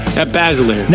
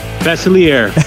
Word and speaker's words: Basiliere: Grant, how did I say it? – Basilier. – Now, French it up for us. – Basilier. – Sadly, I think I can do Basilier better than I can do that.